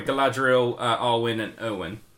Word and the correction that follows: Eowyn: Erwin? – She's the Rohan... – You mean Eowyn? – Eowyn, sorry. – Yeah, Eowyn. – Sorry, the mispronunciation.